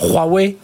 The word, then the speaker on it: Huawei: Et, on le voit l'important, C'est important ce que vous dites, Jean-François, parce qu'on a on a vu Huawei, euh, alors Huawei, voilà pour bien le prononcer,